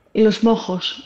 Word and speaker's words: Mozos: Γιατί υπάρχει το τέταρτο της σειράς, Los Mozos de Monleón, αλλά δεν ξέρω να το προφέρω σωστά. Los Mozos. – Ναι, ναι. Los Mojos. Los Mojos.